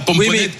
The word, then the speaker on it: pomponnette: Je pense que ça fait tout le monde connaît la pomponnette et le prononce comme ça.